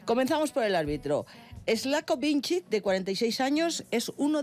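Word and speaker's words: Vinčić: Es el esloveno Slavko Vincic.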